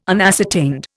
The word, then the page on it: unascertained: Indian